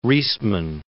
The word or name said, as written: Riesman